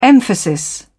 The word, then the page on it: emphasis: British English